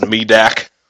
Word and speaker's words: Medak: Am I saying that right? – I think it's pronounced Medak. Yeah, you're fine.